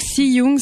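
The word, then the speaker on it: Cy Jung: Donc, Si Young, ça s'écrit C-Y, plus loin, J-U-N-G.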